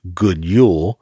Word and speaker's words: God jul: Phonetically, it's pronounced God jul.